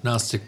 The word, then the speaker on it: Gnostic: Gnostic with a silent G.